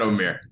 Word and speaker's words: Omier: I really butchered Norchad Omir's name. I feel bad now. Norchad Omier. I said Norchad Omar Omier.